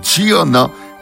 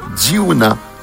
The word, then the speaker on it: Diunna: ben vous allez apprécier Diana Greenleaf, et son prénom s'écrit D-I-U-N-N-A. Diana Greenleaf.